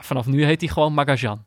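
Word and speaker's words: Magallán: Hij sprak de naam van de nieuwe Argentijnse Ajax-verdediger namelijk op zijn Hollands uit. Met een Engelse G, Magalan. Terwijl het in het Spaans natuurlijk uitgesproken wordt, wordt als... Nee, dit snap ik. In het Spaans natuurlijk uitgesproken wordt als Magajan. Met de klemtoon op de laatste lettergreep.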